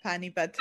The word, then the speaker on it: Panipat: Yeah, Panipat.